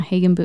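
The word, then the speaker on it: Hagenbuch: Yeah, we're talking about Adam Hagenboo, and there's a lot of controversy over how to pronounce his last name. – He spells it out on his Instagram account. – Oh, Hagenboo. – Yes.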